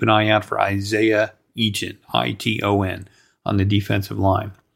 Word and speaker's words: Iton: There's somebody named Isaiah Eaton, and it's spelled I-T-O-N.